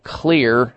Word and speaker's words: Xlear: What you need to do is, at the health food store, start taking something called Clear Nasal Spray, and that's spelled X-L-E-A-R.